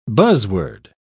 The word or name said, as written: buzzword